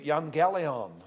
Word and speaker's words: evangelion: It is the word that's pronounced in Greek, evangelion.